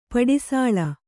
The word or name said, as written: ಪಡಿಸೂಳು